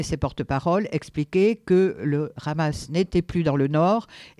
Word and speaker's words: Hamas: non pas le H comme il se prononce en français, comme... le plus souvent, il est muet, voire légèrement aspiré, mais le prononcer à la manière israélienne en insistant sur le « r » du Hamas.